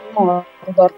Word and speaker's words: Mordor: Attenzione al detto Mordor, anche Tolkien pronuncia proprio così Mordor con una R molto marcata e,